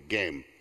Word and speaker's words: Gem: It's spelled G-E-M, but that's how he pronounced it there, Game.